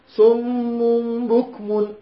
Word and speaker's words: صم بكم: عندنا ميم مشددة وراها إخفاء. صم بك زين؟ هكذا صم بكم. فالميم الأولى الغنة أعلى من الميم الثانية، انتبه، الميم الأولى الغنة أعلى من الميم الثانية، لأن الميم الأولى مشددة، وقلنا الميم المشددة هي كالنون المشددة حركة، زين؟